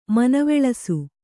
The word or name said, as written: ಮನವೊಲಿಸು